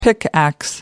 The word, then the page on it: pickax: British English